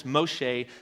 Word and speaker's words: Moshe: In fact, the Jewish New Testament commentary says this, that the 70 sent by Jesus in Luke correspond to the 70 elders, Moshe, that's the Hebrew pronunciation of Moses, elders Moshe appointed in the wilderness who received of the Spirit and prophesied.